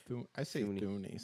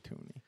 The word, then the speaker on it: Thuney: I don't even know how to say his name. – Thuney. Some people Thuney. Say Toonie. – You say Toonie? I say Thuney.